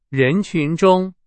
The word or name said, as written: 人群